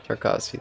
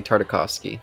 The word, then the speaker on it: Tarkovsky: Tarkovsky, thank you. I keep wanting to say Tartakovsky, but that's not.